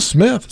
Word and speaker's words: Smythe: It's spelled differently. It's like S-M-Y-T-H-E. – Still Smith. Smythe? – Yeah. Smythe Smith.